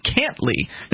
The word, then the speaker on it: Cantlie: They've involved a British hostage named John Cantlie. Now, it's spelled Cant, C-A-N-T-L-I-E, which sounds like can't lie, interestingly enough.